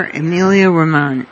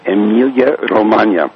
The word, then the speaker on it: Emilia Romagna: I'm here, Emilia Romagna. – Well, let me first correct the pronunciation because it's important. Emilia Romagna.